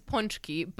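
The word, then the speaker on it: pączki: It's It's ponchki, but like the fact that you pronounce the front part wrong is all right.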